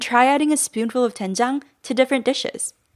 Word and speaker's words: doenjang: Especially if you're new to Korean cooking, Eric recommends starting with doenjang, which is often translated to soybean paste. – It's spelled D-O-E-N-J-A-N-G.